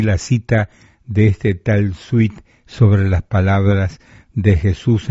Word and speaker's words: Swete: Según un comentarista sobre el Evangelio de Mateo, un escritor de las palabras de Jesús, llamado Sweet, según H.B. Sweet, se escribe S-W-E-T-E.